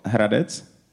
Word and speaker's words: Hradec: Hradec? – Hradec? – Hradec? And they say, no, no, no, you have to roll your R.